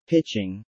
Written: Pitching

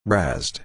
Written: razzed

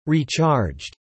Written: recharged

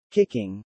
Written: kicking

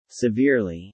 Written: severely

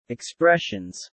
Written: expressions

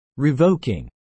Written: revoking

